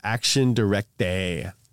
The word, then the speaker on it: Action Directe: By 1986, Renault's debt was halved, and Besset was murdered by the radical communist group Action Directe.